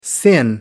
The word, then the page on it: sin: American English